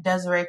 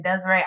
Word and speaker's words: Deseré: And that's at Desiree Cross Ward. My name is spelled very uniquely. I have yet to find another Desiree who spells her name this way. So if you just type in Desiree, I will pop up. And it's spelled D-E-S-E-R-E.